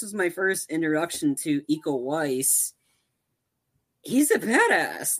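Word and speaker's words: Iko Uwais: Eco Weiss. – Eco Weiss. Okay,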